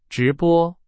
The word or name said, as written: 直播